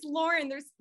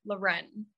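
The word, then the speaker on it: Lorén: A lot of people think it's Lauren. There's technically an accent mark over the E. It makes it Loren.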